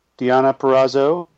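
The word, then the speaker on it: Perazzo: Diana Perrazzo, I believe I'm pronouncing that correctly. – Yes, you're good.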